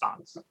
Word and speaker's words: stonks: It's pronounced stonks. Yes.